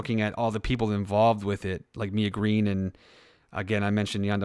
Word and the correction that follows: Misha Green: Misha Green. Is it Misha Green or Mia Green? – Misha Green. – Misha Green. Sorry.